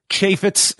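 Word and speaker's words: Chaifetz: We can tell you what the gate was for Dynasty in St. Louis at the, how do you say this? Chevets? – Chaffetz is what Chaffetz. I'm going with. – Chaffetz yes. Arena.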